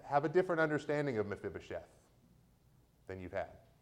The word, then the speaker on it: Mephibosheth: But I say Mephibosheth, and so you're going to say Mephibosheth too.